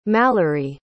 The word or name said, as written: malerie